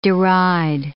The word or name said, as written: deride